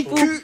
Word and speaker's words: Quipu: Et si vous pensiez que c'était déjà vertigineux d'imaginer l'Aniakea, attendez qu'on vous parle de comment Kipu, ça s'écrit Kipu. Q-U-I-P-U.